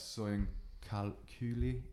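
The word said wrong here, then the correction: Söyüncü: Is that the Turkish? – Oh, Soyuncu. – Yeah, the centre back.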